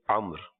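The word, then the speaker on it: عمرو: اور اس کو عمر پڑھتے ہیں جی. – تو درست جو ہے وہ یہی ہے جس انداز میں یہ لکھا ہوا آپ دیکھ بھی رہے ہیں, اور اس کا تلفظ عمر.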